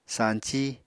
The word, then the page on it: sañcí: Apatani